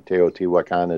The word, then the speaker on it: Teotihuacan: Teotihuacan is the the Spanish pronunciation.